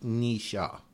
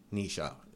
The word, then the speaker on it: Nietzsche: People often get the name wrong. It's pronounced Nisha. Nisha is the correct pronunciation.